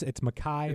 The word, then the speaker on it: Mekhi: No, it's Mekhi.